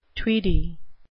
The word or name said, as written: tweedy